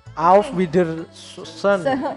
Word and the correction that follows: Auf Wiedersehen: Auf, auf wieder wieder sehen. – Sehen. Auf wieder sehen Auf Wiedersehen. Kalau digabung cepet. Auf Wiedersehen.